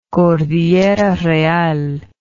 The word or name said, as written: cordillera real